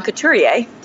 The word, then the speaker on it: Couturier: And I'm not going to rank them yet, but it's Anze Kopitar, who's having a stellar year, and Sean Couturier. Did I pronounce that right? Because I've been working on it. – Yes. Yeah, you got it.